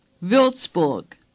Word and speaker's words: Würzburg: Now Würzburg.